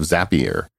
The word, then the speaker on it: Zapier: Zapier rhymes with happier. – Yes. Thank you, Tim, for that correction. – From Wade Foster, CEO of Zapier.